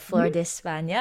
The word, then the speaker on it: Flor de España: Flor de España.